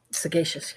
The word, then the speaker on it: sagacious: How do you say that other word? Sagacious? – Sagacious, yeah.